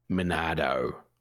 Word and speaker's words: Monado: You're saying the name wrong, by the way. – Monado. – Yes, there you go.